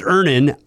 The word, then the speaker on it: Earnin: E A R N I N is pronounced Ernin. I don't know how else you would pronounce it, but they really want to be clear that that's how to pronounce it.